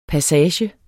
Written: passager

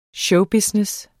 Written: showbusiness

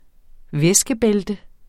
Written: væskebælte

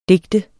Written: digter

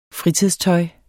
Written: fritidstøj

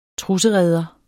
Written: trussereder

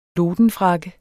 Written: LODENFRAKKE

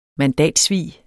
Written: mandatsvig

